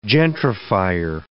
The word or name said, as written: gentrifier